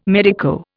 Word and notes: medico: Indian

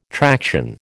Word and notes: traction: American English

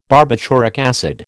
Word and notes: barbituric acid: American English

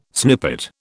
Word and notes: snippet: British English

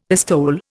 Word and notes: pistole: Indian

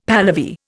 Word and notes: Pahlavi: Indian

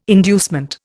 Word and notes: inducement: Indian

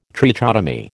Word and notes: trichotomy: American English

